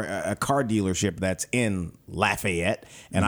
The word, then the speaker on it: Lafayette: And the Lafayette, by the way, I've been trained that it's Lafayette in Louisiana.